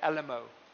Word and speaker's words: Alamo: Is that the right pronunciation, the Alamo? Okay, it is. The Alamo is today the most popular tourist attraction in Texas.